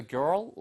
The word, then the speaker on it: girl: 발음 하나만 주의하세요. 이거 girl 이게 아니고요. girl 한 다음에 뒤에 튀기면서 girl. girl. 혀가 이렇게 나왔다가 이렇게 들어오시면서 girl, girl처럼 말하시면 돼요.